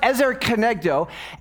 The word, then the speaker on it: ezer kenegdo: So in suitable helper, it's this Hebrew phrase, and it's pronounced ezer kenegdo,